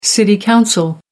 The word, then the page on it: city council: American English